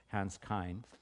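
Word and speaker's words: Küng: But this guy's name is Hans, and the last name is spelled K-U-N-G. He's a Swiss Catholic priest and theologian, so his name is actually, I understand, pronounced Kin or Kine, but it's like K-Y-N, but it's K-U-N-G, so if you're looking him up, Hans Kine.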